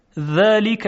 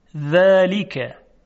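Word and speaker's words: ذلك: خب یک مثال دیگه: ذالک الکتاب لا ریب فیه، ذالک، ذالک. دقت میکنید که صدای ذال صدای ظریفی هست، ذالک.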